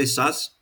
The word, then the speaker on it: Szasz: I say Szasz.